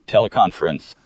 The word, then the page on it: teleconference: American English